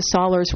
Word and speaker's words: Sollers: I remember about the only thing I remember is Mrs. Sollers rhymes with dollars.